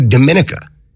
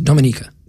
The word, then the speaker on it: Dominica: There was a gay cruise that decided to take into the Caribbean yesterday, a trip to Dominica. – Dominica. – Which, what's that? – It's pronounced Dominica.